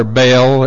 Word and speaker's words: Baal: Now, that's B-A-A-L. And the way you pronounce it is not Baal or Baal. It's Baal. That's the proper pronunciation of it.